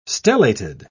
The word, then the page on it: stellated: British English